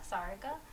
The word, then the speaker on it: Sarika: So, Sarika.